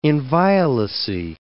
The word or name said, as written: inviolacy